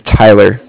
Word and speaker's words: Tyler: My name is Tyler, T-Y-L-E-R.